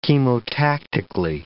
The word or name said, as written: chemotactically